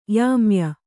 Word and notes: ಯಾಮ್ಯೆ: yāmye; Kannada

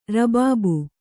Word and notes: ರಬಾಬು: Kannada

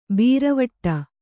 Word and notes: ಬೀರವಟ್ಟ: bīravaṭṭa

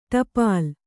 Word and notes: ಟೋಪಲಿ: Kannada

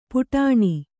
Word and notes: ಪುಟಾಣಿ: puṭāṇi

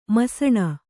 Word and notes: ಮುಸುಣಿ: kannada

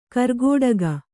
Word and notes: ಕಾರ್ಕೋಟಕ: kannada

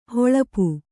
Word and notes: ಹಲಪಿ: halapi

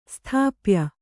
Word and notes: ಸ್ಥಾಪ್ಯ: sthāpya

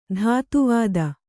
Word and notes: ಧಾತುವಾದ: dhātu vāda

